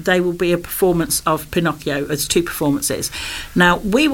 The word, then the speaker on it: Pinocchio: For 2 weeks, and they're going to create the real story of Pinocchio. So we leave Disney far behind. – Excellent. And you pronounced it the proper way as well. – Well, it's Italian, so it has to be Pinocchio.